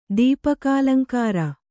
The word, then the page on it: ದೀಪಕಾಲಂಕಾರ: kannada